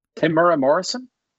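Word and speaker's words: Temuera Morrison: Timura Morrison? – Yeah, I'm pretty sure you said it right.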